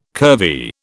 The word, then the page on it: curvy: British English